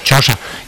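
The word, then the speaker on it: Chioggia: Gioia, no sé pronunciarlo. – Gioia, como dicen ellos, Chaosa, que está fuera, que es una especie de pequeña Venecia, pero esto ya está, digamos, es una peninsulilla.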